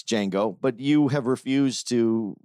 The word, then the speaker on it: Django: And for the record, I always get shit because I pronounce it Django. – Yeah. – When the J is supposed to be silent.